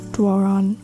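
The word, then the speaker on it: Doiron: that was So Fast by Julie Dwaron. I think that's how you pronounce her name. Did I get it right? Dwaron. Yes, that's how I'll say it.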